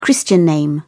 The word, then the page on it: christian name: British English